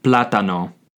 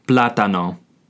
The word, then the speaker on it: plátano: The third word we're going to learn is how to say the banana, which is el plátano. El plátano. This is spelled E-L space P-L-A-T-A-N-O. El plátano. Keep in mind, there is also an accent over the first A on the word plátano.